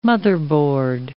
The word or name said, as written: motherboard